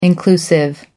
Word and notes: inclusive: British English

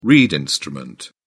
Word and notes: reed instrument: British English

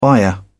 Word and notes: buyer: British English

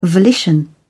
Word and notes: volition: British English

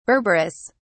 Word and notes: berberis: British English

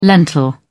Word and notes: lentil: British English

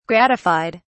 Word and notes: gratified: British English

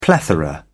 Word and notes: plethora: British English

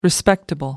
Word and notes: respectable: American English

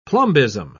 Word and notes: plumbism: British English

